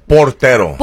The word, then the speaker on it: portero: He is the potrero of Mexico, the goalie. He was brought out the last 15 minutes. Did I say it right? – Portero.